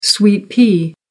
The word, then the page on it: sweet pea: American English